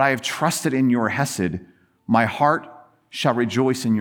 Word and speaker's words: Hesed: The pain and frustration and anger were not wiped away, but rather transformed by entering the world of this untranslatable three-letter, two-syllable word, Hesed.